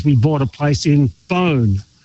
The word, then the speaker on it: Beaune: Well, Beaune, you said it very well.